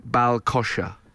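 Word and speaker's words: ball-coise: She says Bal Coys Rock and Roller. – Bal Coys, that was it. She said it's pronounced more like Bal Kosher.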